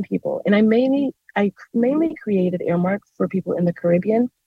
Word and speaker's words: Heirmark: It's spelled Airmark, like air, like H-E-I-R-M-A-R-K.